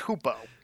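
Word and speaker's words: Hoopoe: I know it as Hoopo. – Yeah. – And I mean, that's the way you pronounce, that's the proper pronunciation of that.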